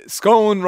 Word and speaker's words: scone: Scone rhymes with moan, says P.